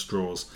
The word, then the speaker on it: Strauss: It's not mm-hmm. Strauss, is it? It's pronounced Strauss.